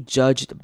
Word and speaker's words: judged: 好，看到最后一句，他说 "And I don't want to be judged by my boss." 好，注意到 "judged" 这个字的 d 会不发音，直接念 "judged"，只有最后面的 t 才要发音，前面的 d 是轻音，是不发音的 "judged."